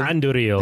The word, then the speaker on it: Andúril: Andúril. – Thank you. – Forged from the shards of Narsil. – Yeah. – Do you have to say it like that the whole time? – Yes, you do.